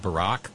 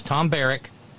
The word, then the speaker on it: Barrack: I've read several of these stories, and every time I see his last name, I want to pronounce it Barack. No, this is Tom Barrack.